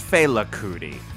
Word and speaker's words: Fela Kuti: Am I saying that correctly? Is it Fela? – I believe it's Fela Kuti.